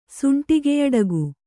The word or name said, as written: ಸುಂಟಿಗೆಯಡಗು